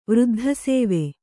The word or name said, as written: ವೃದ್ಧಸೇವೆ